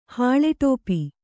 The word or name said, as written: ಹಾಳೆಟೋಪಿ